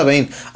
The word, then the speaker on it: Bane: No, pues se pronuncia Bane.